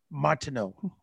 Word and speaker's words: Martineau: His name is Steve Martineau. I got it right now, didn't I? There we go. The French name, the T is not pronounced.